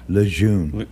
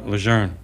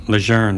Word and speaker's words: Lejeune: Lejeune. – No, Lejeune. They changed it. Yeah, they changed it. Yes. – It says Lejeune. – Lejeune, that's how they pronounce it now.